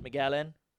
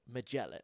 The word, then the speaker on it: Magellan: Magellan, yeah, as Americans say it, as we found out through our research. But it's Magellan.